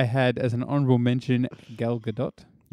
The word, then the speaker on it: Gal Gadot: Gal Gadot is the pronunciation. – Yes. Okay. – I looked it up. – Okay. – Because we had that discussion the last time I said her name, and it's Gadot.